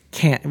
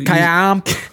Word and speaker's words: Caamp: Is that how you say it? – Camp. Well, you Camp. – They have two A's. – That's a double A. – C-A-A-M-P.